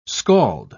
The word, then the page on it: skald: British English